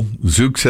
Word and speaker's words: Zoox: Amazon's fully autonomous Zoox, Z-O-O-X, how would you pronounce that? – Zoox.